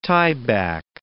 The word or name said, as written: tieback